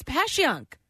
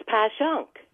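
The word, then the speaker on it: Passyunk: People from South Philly are the ones telling me how to say it. It's Pashunk. – It's Pashunk.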